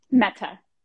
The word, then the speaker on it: Mehta: Mehta.